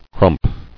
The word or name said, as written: Crump